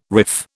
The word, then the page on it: riff: British English